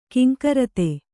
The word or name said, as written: ಘೀಂಕೃತಿ